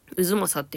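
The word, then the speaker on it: 太秦: はい、安泰の鯛って書いてうずまさって読むらしいんですけど、そのうずまさの中にある三好みたらしっていうお団子屋さんを教えてもらったんですね。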